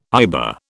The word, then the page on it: IBA: British English